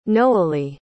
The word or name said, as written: nohely